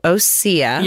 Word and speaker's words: Osea: It's spelled just Osea, yeah. So Osea.